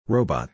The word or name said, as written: Robot